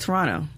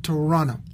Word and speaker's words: Toronto: It's Toronto. – Toronto. – You don't put emphasis on the toe. You don't say... If you say Toronto, then that means you've never been to Toronto.